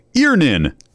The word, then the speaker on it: Earnin: E-A-R-N-I-N is pronounced Earnin. I don't know how else you would pronounce it, but they really want it clear that that's how to pronounce it.